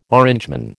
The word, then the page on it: Orangeman: American English